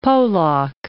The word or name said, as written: Polack